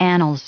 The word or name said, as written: annals